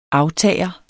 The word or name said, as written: AFTAGERNE